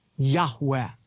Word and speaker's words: Yahweh: In Hebrew, it would be composed of the four Hebrew letters, Yud Heh, Vav Heh, which most Semitic scholars believe is pronounced a breathy Yahweh.